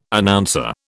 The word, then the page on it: Announcer: British English